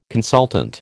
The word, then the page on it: consultant: American English